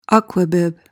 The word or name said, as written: aquabib